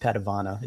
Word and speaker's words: Padavona: It's pronounced Padavona. It's just a family thing.